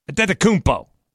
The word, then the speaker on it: Antetokounmpo: Learn how to say his name. The joke's played out. Antetokounmpo. It's easy.